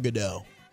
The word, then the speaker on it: Gadot: Gal Gadot. – Gal Gadot. – It's got a T at the end, but the T is silent.